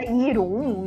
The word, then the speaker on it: Jeroen: Se escreve, né? Jeroen, mas se fala Irum, um negócio assim.